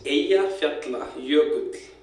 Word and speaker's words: Eyjafjallajökull: Eyjafjallajökull.